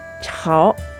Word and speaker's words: 炒: いちゃうかもしれませんけれどもやめるつもりはありませんのでどうぞお見捨てなきようにあそうそう今回のですね「チャオ」というのしっかり 3 声で出してくださいねこれを同じ発音で「チャオ」と一声にすると「湯がく」という意味になってしまいます「チャオボーツハイ」だとほうれん草を湯がくという意味になってしまいますのでご注意をそれではまた。